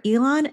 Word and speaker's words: Elan: And remember, Elon is spelled E-L-A-N.